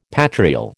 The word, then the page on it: patrial: American English